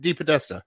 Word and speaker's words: DePodesta: DePodesta.